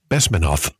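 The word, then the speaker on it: Bezmenov: And you're talking about Yuri, how do you pronounce his name? Besmanov. And the four? – Yeah, maybe that was it. Yes. – Yep. Yuri Besmanov. Yeah.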